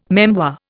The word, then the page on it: memoir: Indian